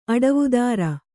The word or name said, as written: ಅಡವುದಾರ